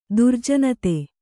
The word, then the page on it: ದುರ್ಜನತೆ: kannada